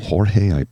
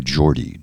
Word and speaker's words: Jordi: Jorge, would pronounce it if he was Spanish, but he goes by Jordi, J-O-R-D-I, Rossy, Jordi Rossy.